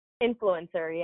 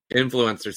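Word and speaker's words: INFLCR: It's NIFLCR. – Influencer, yeah. – Influencer. See, I knew I was going to get that wrong.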